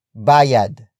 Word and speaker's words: bayad: Bayaran is spelled as B-A-Y-A-R-A-N. It is a command to pay something. It comes from the root word bayad. Bayad. Bayad, which is spelled as B-A-Y-A-D.